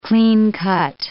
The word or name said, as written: clean-cut